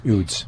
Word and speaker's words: Eudes: And one of the saints, I think it was Saint John, how do you say it? Udes? E-U-D-E-S. – Udes.